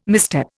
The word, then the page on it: misstep: Indian